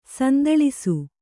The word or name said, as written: ಸಂದಳಿಸು